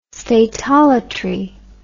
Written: statolatry

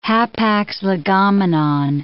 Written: hapax legomenon

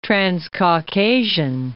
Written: Transcaucasian

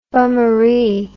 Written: bummaree

